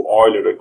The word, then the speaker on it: Euler: É, Euler é como a gente pronunciaria, a pronúncia em português, mas, é, enfim, a pronúncia da língua materna dele, da língua do Euler, a gente pronuncia Euler, né? Porque esse E, U tem som de OI, tipo Deutschland, né?